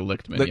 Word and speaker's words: Lichtman: I definitely heard the C sound, the Lichtman. – Lichtman, okay.